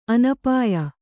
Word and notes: ಅನಪಾಯ: anapāya

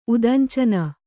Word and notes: ಉದಂಚನ: udañcana

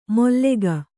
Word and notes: ಮೊಲ್ಲೆಗ: Kannada

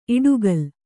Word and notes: ಇಡುಗಲ್: Kannada

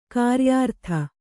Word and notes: ಗಿರಿಯಾರತಿ: giriyārati

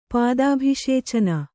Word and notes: ಪಾದಾಭಿಷೇಚನ: kannada

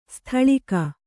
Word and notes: ಸ್ಥಳೀಕ: sthaḷīka

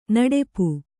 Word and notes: ನಡಪು: naḍapu

